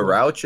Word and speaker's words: Rauch: Melissa Rauch is how you pronounce her last name.